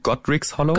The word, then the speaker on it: Godric's Hollow: Du hast einen Ort genannt, Eldrick's Hollow, von dem ich gar nichts weiß. Kannst du mir darüber noch mal kurz ein bisschen erzählen? – Dann habe ich schlecht ausgesprochen, Godric's Hollow.